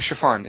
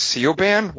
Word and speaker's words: Siobhan: Spell the name chiffon. It's an Irish name. Spell it. – S-A-I-O-B-H-A-N. – Yeah, except without the H. Like, I always thought it was Sioban. What? But that's like chiffon.